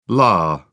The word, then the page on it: la: British English